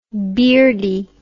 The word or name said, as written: beardie